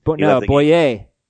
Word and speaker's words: Bouye: Bouye, is that how you pronounce it? – Bouye. – Bouye.